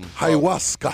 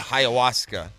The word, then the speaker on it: ayahuasca: Ayahuasca. – Ayahuasca.